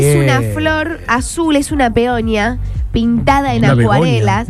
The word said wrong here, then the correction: peonía: Una peonia. Peonía. Ahí está, lo dije bien.